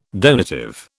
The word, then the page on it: donative: British English